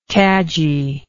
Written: cadginess

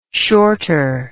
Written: shorter

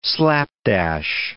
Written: slapdash

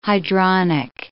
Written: hydronic

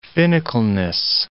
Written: finicalness

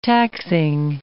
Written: taxing

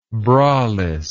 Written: braless

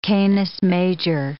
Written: Canis Major

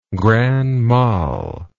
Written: grand mal

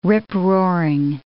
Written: rip-roaring

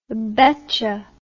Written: betcha

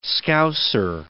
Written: Scouser

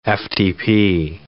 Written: FTP

